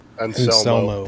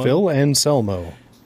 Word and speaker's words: Anselmo: Anselmo.